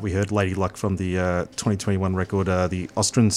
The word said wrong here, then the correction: Asturian: Session. Am I saying that right? – Asturian.